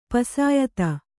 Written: ಪುಸಿಯೋತ

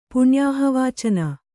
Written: ಪುಣ್ಯಾಹವಾಚನ

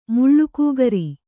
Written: ಮುಳ್ಳುಕೂಗರಿ

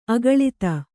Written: ಆಕುಲಿತೆ

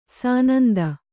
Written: ಸನಂದ

